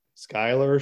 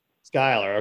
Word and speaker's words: Schuyler: Schuyler County. – Schuyler. – What is it? – Schuyler.